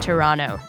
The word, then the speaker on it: Toronto: Toronto. Sorry, I meant Toronto. You don't pronounce the T.